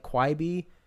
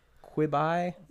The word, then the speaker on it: Quibi: Because I didn't even know how to pronounce it. I was saying like Quibi, Quibi. – Quibi.